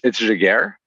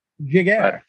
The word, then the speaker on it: Jaguer: And with me to talk about that is Stephen Jaguer. Did I say that right, Stephen, by the way? I'm sorry. – It's okay. It's Jaguer. – Jaguer. – Make it sound French, but a bit of a flourish.